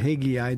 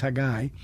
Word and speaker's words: Haggai: I felt specifically that the Lord, it was like Haggai, and I, you know, for years I pronounced it Haggai. That's not true. It's Haggai.